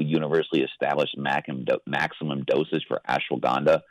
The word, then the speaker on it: ashwagandha: But I was just wondering: is there any kind of restrictions on ashwagandha? Is that how you say it? – Ashwagandha. You're saying it correctly.